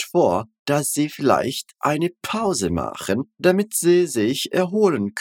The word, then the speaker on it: Pause: A gente não vai falar pause, né? Pause. A gente vai falar pause. Pause. Percebe que o P ele é bem explosivo e a gente tem a terminação com E, né? Não com A.